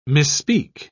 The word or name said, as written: misspeak